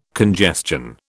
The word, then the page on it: congestion: British English